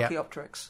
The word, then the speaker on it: Archaeopteryx: Archaeopteryx. – Oh, yeah. It's not a silent P. It becomes a...